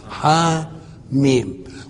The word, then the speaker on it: حم: هنا بينطق بإيه؟ ح، لأنه لو بينطق بالمسمى يقول حم حما حما، إنما هنا ما بينطقهاش كده، بقول إيه؟ حاميم.